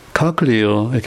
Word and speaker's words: cochlea: C O C H L E A 카클리어 이렇게 발음하는데 이게 그 어, 쉽게 얘기해서 우리 저 우리 말로 달팽이관이라고 그러죠.